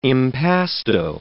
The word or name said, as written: impasto